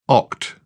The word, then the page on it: oct: British English